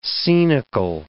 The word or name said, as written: scenical